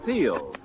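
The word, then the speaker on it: Teel: This is Ken Carpenter on behalf of Teal, inviting you to listen again next week. And remember, for laughs, it's R-I-L-E-Y, Riley, and for lovely smiles, it's T-E-E-L, Teal.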